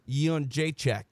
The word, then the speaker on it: Jędrzejczyk: Joanna Jędrzejczyk. That's what I think. – All right. Now let's hear her say. – All right. Let me press this button here. – Joanna Jędrzejczyk.